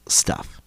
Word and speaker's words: Stuhff: It's pronounced, it's spelled S-T-U-H-F-F. Stuff.